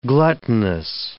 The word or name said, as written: gluttonous